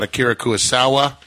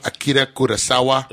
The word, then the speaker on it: Akira Kurosawa: We talked about Akira Kurosawa. I said that wrong. Akira Kurosawa. – There we go.